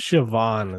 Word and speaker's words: Siobhan: Oh, it's Siobhan is how you say it.